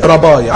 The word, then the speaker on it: rabbi: لا، ده هو كان يقصد ربي، لو كتبناه بالانجليزي R-A-B-B-I اللي هي بتنطق زي رباي، عارفين لقب رباي؟